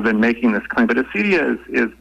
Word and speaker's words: acedia: And in particular, this concept of, and I don't even know how to pronounce it, but is it acedia, acadia? – Acedia. – Acedia.